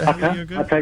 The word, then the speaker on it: Hatha: When you know, yoga has varied methods, and when you think of yoga in the West, you're probably thinking of Hatha, I think is how you pronounce it. – Hatha yoga.